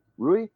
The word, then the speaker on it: Ruy: Rui? It's spelled R-U-Y.